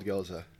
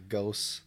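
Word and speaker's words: Gose: Very mild goza. Mm-hmm. Definitely mild. – With the, yeah. It's good. – And yes, it is pronounced goza, not ghost, or what's whatever the hell people say. – Isn't there like a German one too for like a goose? – Yeah, G-U-E-Z-E.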